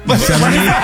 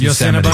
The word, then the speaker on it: Yosemite: Yosemite? You didn't say it right, but we know what you meant. Yeah. Yes, Yosemite.